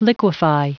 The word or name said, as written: liquefy